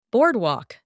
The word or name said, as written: boardwalk